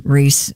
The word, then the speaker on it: Rhys: Does he pronounces it Reese? – Reese? – Does he? I don't know. – Oh, it is Reese.